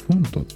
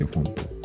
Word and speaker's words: PHONTO: で、同じようなやつで、フォントっていうあのアプリがあって、それも、そっちはね、いつもよく使うんですけど、フォントっていうのは PHONTO って書いてフォント。フォントって読むと思うんだけど。